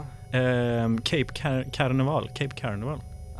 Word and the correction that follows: Canaveral: Canaveral ja, exakt.